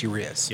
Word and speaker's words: Urias: Do you know how to say it yet? – It's Urias. – I swear to God, they say it different. – It's Urias. – Well, they probably do say it different. They're not meaning disrespect. They just don't know how to say it. – I'm 97.5% sure it's Urias.